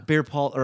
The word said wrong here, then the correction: Bell's palsy: Bell's palsy.